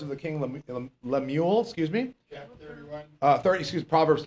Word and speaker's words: Lemuel: The prophecy, Lemuel, that's how you're supposed to say it.